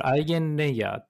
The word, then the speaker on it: EigenLayer: で、 2 つ目が、えこれ、アイゲンレイヤーって読むんですけど、えっ、ー、と、はい、アイゲンレイヤー、はい。アイゲンレイヤー、そうそう、アイゲンレイヤーの話。